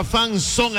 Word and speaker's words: fan zone: Se escribe fan, F-A-N. Z-O-N-E, se pronuncia son, fan son, escríbelo así, fan sone al WhatsApp Olímpica.